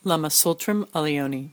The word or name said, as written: Lama Tsultrim Allione